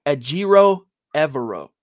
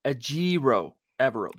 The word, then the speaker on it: Ejiro Evero: Ejiro Evero. Ejiro Evero. That's how you say this cat's name, by the way.